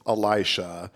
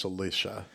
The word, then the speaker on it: Elisha: I grew up with Elisha, but okay. I hear a lot of Catholics pronounce it as Alicia.